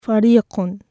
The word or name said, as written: فَرِيقٌ (فُرُوقٌ)